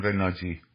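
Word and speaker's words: Nagy: اگر ناگی تونست پنج و شیشه یا به قول مجاری ها ناجی تلفظ مجاریش تلفظ انگلیسیش میشه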